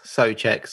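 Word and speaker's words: Souček: Well, just to throw something further in there, my understanding is that it's pronounced tss. Soucek. So it's like a T at the beginning. Soucek.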